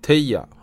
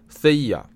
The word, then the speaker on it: 特伊亚: 特伊亚啊，或者叫塞伊亚，这两种发音都可以。